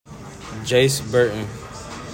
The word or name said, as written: JACE BURTON